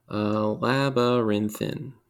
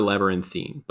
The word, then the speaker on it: labyrinthine: How would it be pronounced? – Labyrinthin. Or, labyrinthin. – So, or labyrinthine, but the primary pronunciation is labyrinthin.